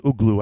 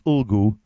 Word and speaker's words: Ulgu: I always call it Uglu. I say that wrong. It's Ulgu.